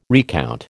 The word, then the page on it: recount: American English